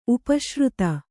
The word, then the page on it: ಉಪಾಶ್ರಿತ: upāśrita